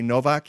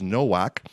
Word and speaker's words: Nowak: David Nowak, and that's how you pronounce his last name. That's how he pronounces his last name. I say Novak. He says Nowak.